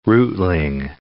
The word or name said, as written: rootling